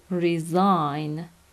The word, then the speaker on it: resign: فعلش میشه resign، استعفا دادن. تلفظش رو هم توجه کنید، resign.